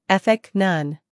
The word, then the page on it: ethic: ˈeθɪk